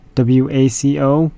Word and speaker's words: Waco: Since I mispronounced this band name from a couple shows ago, WACO. The label finally got back to me and let me know how to pronounce it. Band is called Waco, so we'll end it off with Waco.